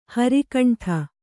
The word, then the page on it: ಹರಿಕಂಠ: hari kaṇṭha